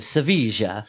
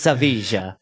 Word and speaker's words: Sevilla: I pronounce it like I'm a native. Sevilla. It's Sevilla.